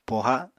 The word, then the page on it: poha: Apatani